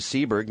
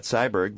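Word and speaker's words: Syburg: I've already insulted my guest in the studio by mispronouncing his last name, which is Seberg, not Cyberg, but it's spelled Cyberg, but it's correct. – Pronounced Seberg. It's S-Y-B-U-R-G.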